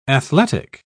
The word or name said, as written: athletic